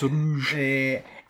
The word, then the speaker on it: Roeg: It turns out I do not know how to say his second name. – Nicholas Roeg.